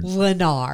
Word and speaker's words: Lennar: In New Fairview, Texas, to Lena. – Nope, that's not accurate. How do you say that word? – Lennar.